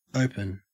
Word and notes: open: Australian English

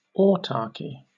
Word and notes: autarchy: British English

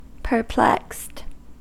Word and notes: perplexed: American English

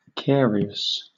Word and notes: carious: British English

